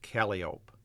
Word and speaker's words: calliope: No steamboat on the upper Mississippi River ever had a calliope. On the river, it was always pronounced calliope.